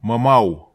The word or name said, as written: mamau